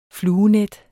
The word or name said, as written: fluenet